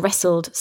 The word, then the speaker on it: wrestle: So we have a silent W in wrestle. W R E S T L E for to wrestle. – That's right. This is very similar to the word uh, to write with a pen, to write. W R I T E, another silent W. – It's also interesting to point out that in the word wrestle, we don't really pronounce the T. – That's right. We almost have two silent letters here.